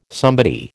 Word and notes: somebody: American English

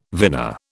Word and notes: vina: British English

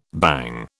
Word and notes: bhang: British English

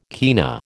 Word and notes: kina: American English

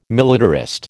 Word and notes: militarist: American English

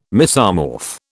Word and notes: mesomorph: British English

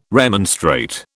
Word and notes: remonstrate: British English

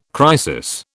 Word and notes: crisis: British English

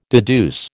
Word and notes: deduce: American English

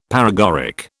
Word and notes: paregoric: British English

